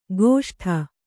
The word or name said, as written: ಗೋಷ್ಠ